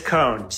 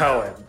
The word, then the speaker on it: Cone: I'm good, Jason, and it is Cohen. C-O-N-E, pronounced Cohen, not Cohen.